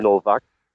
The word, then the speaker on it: Novak: I think the last name would be pronounced Noack. Is that correct? – Sure. Novak. – Go. Novak. – Novak. – Okay, it's not a W, it's a double V in this case. – Well, there is it's no a W, but pronounced in Europe differently.